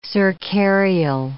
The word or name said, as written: cercarial